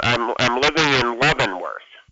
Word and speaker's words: Leavenworth: It just went to the familiar, and I didn't, and you pronounced it correctly. It was my ears that... – Right, yeah, it was Leavenworth.